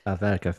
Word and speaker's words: Alverca: I was going to say Alverca. Is that good? – Almeca. – Alverca, thank you.